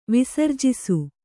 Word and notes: ವಿಸರ್ಜಿಸು: visarjisu

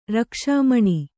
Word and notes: ರಕ್ಷಾಮಣಿ: Kannada

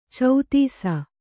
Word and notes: ಛತ್ತೀಸ: kannada

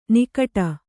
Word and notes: ನಗಾಡು: kannada

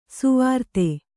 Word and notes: ಸ್ವರಿತ: Kannada

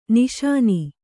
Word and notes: ನಿಶಾನೆ: kannada

